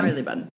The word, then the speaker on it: Marylebone: It's on Regent Street, and it's situated in the district of Marylebone. Did I say that right? – Marylebone. Yeah.